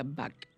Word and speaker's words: Bach: Y usted es extranjero y usted no tiene por qué saberse cómo se pronuncia, pero se pronuncia Bach. O sea, no se pronuncia la CH, sino la C nada más.